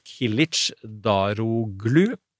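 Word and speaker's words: Kılıçdaroğlu: Eh, så Erdogan står altså mot Kilic Daroglu, Eh, som jeg eh, researcha i stad. Det er sånn eh, det uttales, sånn cirka.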